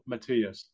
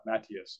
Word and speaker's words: Matias: It's Subriel Matias, or Matias.